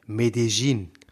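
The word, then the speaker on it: Medellín: waren in und so sprechen die es aus. Die waren in Medellin. Ja, das ist ja auch richtig ausgesprochen auf dem Dialekt.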